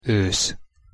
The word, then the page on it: osz: Hungarian